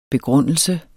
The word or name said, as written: begrundelse